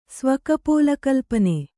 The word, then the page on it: ಸ್ವಕಪೋಲಕಲ್ಪನೆ: svakapōla kalpane